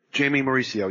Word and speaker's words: Jaimy Mauricio: Jaimy Mauricio, yes, that's correct.